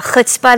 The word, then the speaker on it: chutzpah: The way that I view myself, the chutzpah that I have. Did I say it right? – Thank you. You said it beautifully.